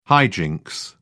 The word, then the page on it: high jinks: British English